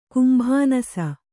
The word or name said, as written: ಕುಂಭಾನಸ